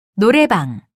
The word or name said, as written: 노래방